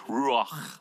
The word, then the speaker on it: Ruach: It's called Ruach, and the proper pronunciation has a very hard H or CH sound at the end.